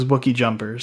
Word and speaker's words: Wookiee Jumper: Yeah, so I mean, hey, if you want to play Destiny and you're on Xbox One, my username is Wookie Jumper.